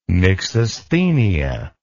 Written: myxasthenia

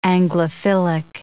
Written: Anglophilic

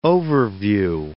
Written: overview

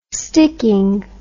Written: sticking

